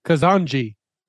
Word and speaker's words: Kazandzhy: And then that ZH at the end makes a Z sound. – Okay. – So Kazandzhy is like the legit pronunciation, but it's easier to just tell people the word cousin and the letter G.